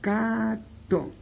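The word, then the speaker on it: kato: Gato se pronuncia o se traduce por cato, cato.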